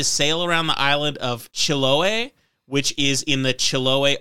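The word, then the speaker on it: Chiloé: Yeah, so it's Chiloe. – Chiloe. – If the accent is where you're saying it is.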